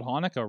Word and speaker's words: Hanukkah: The C is silent in Hanukkah.